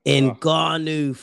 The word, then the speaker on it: Ngannou: Inagu. I'm not pronouncing his name right. – Ngannou.